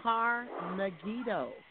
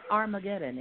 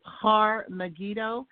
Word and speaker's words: Har-Megiddo: It's actually Har-Megiddo. It's not Armageddon.